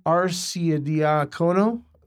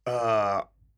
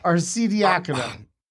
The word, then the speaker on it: Arcidiacono: They have Ryan Arcidiacono, which I definitely didn't pronounce right. But it's Arcidiacono. – Arcidiacono.